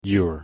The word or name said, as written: youre